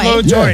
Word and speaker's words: Pablo Torre: Pablo Torre, yeah.